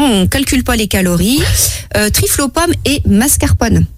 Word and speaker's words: trifle: Et là, je vous présente un trifle. – Un trifle, donc ça s'écrit T-R-I-2-F-L-E? – Non, T-R-I-F-L-E.